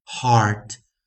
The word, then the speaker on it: heart: 去做一个双儿啊，做一个双儿音的啊，双元音的一个发音，deep sound 啊的一个发音，a r art heart heart heart 啊，所以这样子的发音，我们还是把它归类成 variant 破音形态啊。为什么呢？因为前面的 e 没发音，没有发音啊，h e a r t 就好像这样子，heart h a r t 的 heart heart heart。